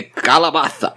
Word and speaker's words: Calabaza: Noel de Calabaza. – Like how you're giving it the Spanish pronunciation? – Of course.